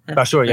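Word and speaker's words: Bachour: you know, Antonio Bashor, like he, is that how you say his name? – Bashor. Yeah.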